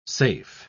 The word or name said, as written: safe